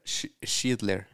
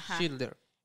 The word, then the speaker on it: Schindler: Es, ¿Cómo se pronuncia esto? – Schilder. Schindler. – Schindler.